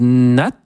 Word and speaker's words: Nat: Et on va ouvrir cette nouvelle saison par un hommage à un très grand pianiste français du siècle dernier qui s'appelait Yves Nat, on prononce bien le T, absolument, voilà, et dont la devise était « rien pour le piano, tout pour la musique ».